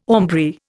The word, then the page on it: aumbry: Indian